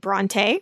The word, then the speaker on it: Brontë: Oh my, there's a controversy about, is it Brontë, which admittedly is the way I always pronounced it, or Brontë, which seems to be the way the family pronounced it. Dictionaries will largely point you toward Brontë. Merriam-Webster says, and I quote, "When our research shows that an author's pronunciation of his or her name differs from common usage, the author's pronunciation is listed first." So we are stuck with Brontë.